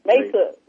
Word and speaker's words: Mesa: Mesa, yeah.